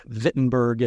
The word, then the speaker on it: Wittenberg: And that's what prompted Luther to nail his 95 discussion topics to the door of the church in the German city of Wittenberg, or Wittenberg, if you're pronouncing it like a German.